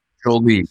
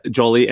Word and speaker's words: Jolie: Is this Jolie? Is that how you say it? – Jolie. Yep. – Jolie.